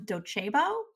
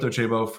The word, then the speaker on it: Docebo: You crushed it. And yeah, happy to be here. – So we would love to kick off with you just telling us a little bit about yourself. Where do you work? What do you do? – As you mentioned, I work at Docebo, well pronounced. Not everyone gets that the first time.